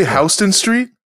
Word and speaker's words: Houston Street: They're cheesy I Heart New York tourist mugs you bought from some tourist shop on Houston Street. You bought them with your girl on the first vacation you ever went. – Did you say Houston Street? – Yeah, that's how you pronounce it.